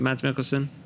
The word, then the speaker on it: Mads Mikkelsen: This frustrating but understandable story of Mads Mikkelsen. Am I saying that right, Mads Mikkelsen? – Yes, you are.